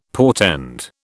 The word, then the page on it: portend: British English